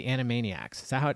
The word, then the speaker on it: Animaniacs: Anyway, and then we got a lot of emails about the Animaniacs. Did I say that right? – Yep. – I did. I'm getting better at this. – Yep. – But Bobby says, I still remember every word of the Animaniacs' Nations of the World song.